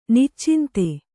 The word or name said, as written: ನಿಚ್ಚಿಂತೆ